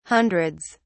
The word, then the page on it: hundreds: British English